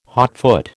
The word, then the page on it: hotfoot: American English